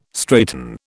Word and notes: straighten: British English

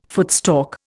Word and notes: footstalk: Indian

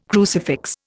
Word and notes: crucifix: Indian

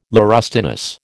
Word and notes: laurustinus: American English

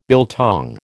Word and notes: biltong: American English

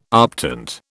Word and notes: optant: British English

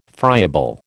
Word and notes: friable: American English

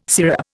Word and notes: syrup: Indian

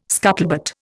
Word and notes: scuttlebutt: Indian

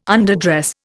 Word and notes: underdress: Indian

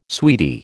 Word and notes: sweetie: American English

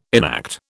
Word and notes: enact: British English

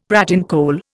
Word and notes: pratincole: Indian

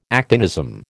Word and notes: actinism: American English

